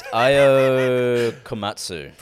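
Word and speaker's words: Ayao Komatsu: It's Ayo Komatsu. – Oh, it's pretty close. – Chicken Katsu. – Okay, what pronunciation, sorry? – Ayo. A-Y-A-O.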